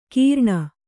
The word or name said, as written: ಕೀರ್ಣ